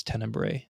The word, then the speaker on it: Tenebrae: And then I went on to watch Tenebla. – That's how you say it? – Oh, no, I think it's Tenebrae.